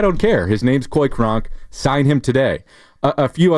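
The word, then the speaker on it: Cronk: That is spelled C-O-Y-C-R-O-N-K. – Kronk? – Coy Kronk. – Like Kronk from Emperor's New Groove? – That's exactly. – Oh, yeah. It's all coming together. – That's exactly right.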